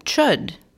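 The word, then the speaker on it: Chöd: Well, one thing that I did was, I think one time we were talking on Clubhouse and you suggested chud, that meditation. – Oh, right. – Chud practice. Am I saying that correctly? – Yeah, that's how I say it.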